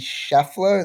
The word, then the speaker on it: Scheffler: The first one off the board is a prediction from Kyle, who predicted that Scotty Scheffler, is that how you pronounce it, Kyle? – Yep, that's it.